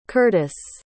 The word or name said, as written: curtiss